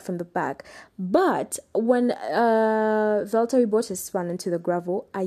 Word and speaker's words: Valtteri Bottas: But before the whole qualifying session ended, Valtteri Bottas, I actually didn't know his name is supposed to be pronounced as Valtteri Bottas. That's how he pronounces his name.